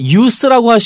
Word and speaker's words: use: Yeah, the verb form has a kind of a stronger z sound. Use. – 네, 그래서 동사는 z 발음이고 명사는 s 발음이에요.